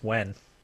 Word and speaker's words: Nguyen: It's Wen.